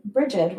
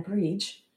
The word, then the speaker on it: Brigid: I know I'm going to make a few people upset by pronouncing her name Brigid rather than Breege but she is a goddess that was venerated in many different areas, and not everyone called her Breach.